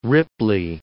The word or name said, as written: Ripley